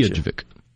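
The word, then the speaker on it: Utqiaġvik: You care to pronounce that? And there's a dot on top of the G. What does that mean? – Utqjevik. – Yeah. Well, the sun is set there. And say it for me. – Utqjevik. – Utqjevik. Gotcha. Utqjevik. Oh, yeah. It's an I and an A. Okay. Utqjevik. Did I say that right? – I think that's right.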